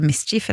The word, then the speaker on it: MSCHF: Den drøyeste varianten er kanskje de gigantiske røde gummistøvlene til kunstkollektivet MSCHF, altså Mischief, regner jeg med at det uttales, som tok over sosiale medier etter at diverse kjendiser og influensere brukte dem.